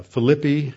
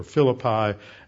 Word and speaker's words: Philippi: and then went to Philippi, as it's pronounced in Greek, or Philippi,